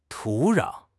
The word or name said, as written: 土壤